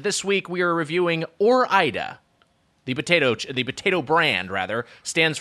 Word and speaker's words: Ore-Ida: I think Ore-Ida is close. I mean, I think there's a case for that because it is like Oregon. And so you start to do, if you start to pronounce that next syllable, there's a case for Ore-Ida. But I believe it's Ore-Ida is the correct pronunciation.